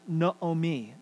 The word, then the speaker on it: Naomi: And if you've ever read this book, and it's a book about women, you have a character named Naomi, properly pronounced Naomi.